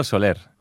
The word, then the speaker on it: Soler: Also muss man eigentlich sagen, Alvaro Scholler, ne? – Nee, Alvaro Soler.